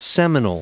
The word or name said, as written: seminal